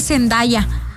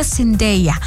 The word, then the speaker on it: Zendaya: Me gusta decir Zendaya porque ella ya dijo la otra vez en una entrevista que toda la gente le dice Zendaya, pero que significa, se pronuncia Zendaya.